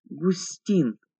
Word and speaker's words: Густин: Приподняв шляпу, он представился мне как Роберт Андреевич Густин с ударением на последнем слоге.